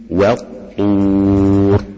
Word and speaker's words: والطور: والطور، تلاحظ في حاجة هنا إن الواو قبل الطاء تنطق مرققة وليست مفخمة، كثير بيقع في خطأ لك وا وا والطور وط هكذا،